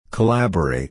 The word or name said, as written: collaborate